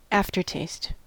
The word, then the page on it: aftertaste: American English